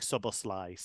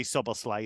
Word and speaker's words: Szoboszlai: And it, apparently in Hungarian, it's not massively different. There's just a slight difference on how you say the final syllable. But he has said in the Bundesliga, Dominic Szoboszlai. So we'll see if that is maintained or not. Obviously, we know the Premier League now do their pronunciation videos as well. But I think if you say Szoboszlai, you're not too far wrong.